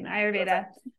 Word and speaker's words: Ayurveda: So how did you transition from that to the wellness space and get into Ayurveda? Am I pronouncing it right? I realize. – Yes. Yep. You're pronouncing it right. Ayurveda.